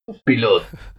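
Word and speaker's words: Pilote: Ik ga natuurlijk de uitspraak verkeerd doen, maar ja. Pilot? – Piloot.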